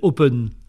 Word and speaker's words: Hopen: Alors je connais un peu le groupe qui va venir qui s'appelle Open, alors ça s'écrit H-O-P-E-N, donc en anglais Hope, c'est l'espoir, l'espérance justement, et donc du coup ça vaut vraiment le coup de venir quel que soit l'âge qu'on a hein.